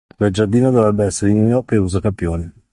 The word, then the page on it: giardino: wloski